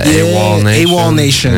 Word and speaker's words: AWOL Nation: We had a lot of, how do you say, Owl Nation? Is that their name? You know what I'm talking about? The guys that made Sail? – Oh, yeah. – AWOL Nation. Yeah. Thank you.